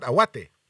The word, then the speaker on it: Aouate: לא, כי הם פשוט הספרדים קוראים את האותיות באנגלית, זה A-O-U-A-T-E. – נכון. – מבטאים את ה-E. – נכון. – ה-E זה A.